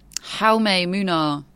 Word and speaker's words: Jaume Munar: Jaume Munar. – Jaume. – By a Spanish speaker today.